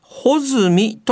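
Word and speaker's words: 八月一日: まあ、そのため、 8 月 1 日と書いて、穂積と読みます。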